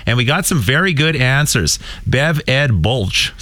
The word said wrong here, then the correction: Bulick: Am I saying that right? – Bulick. – Bulick. – I apologize, Bev.